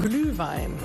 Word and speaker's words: Glühwein: Glühwein.